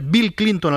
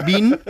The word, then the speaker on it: Bill Clinton: Ha mostrado serias dificultades no ya para hablar inglés, sino para pronunciar Bill Clinton, al que llama Bin. – Porque Bin Clinton...